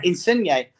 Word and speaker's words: Insigne: Insigne? Is that how you pronounce it? – Yeah, Insigne, yeah. – Yeah, I'm glad that, because I'm not very good at pronouncing names at times. Yeah, Insigne.